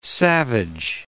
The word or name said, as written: savage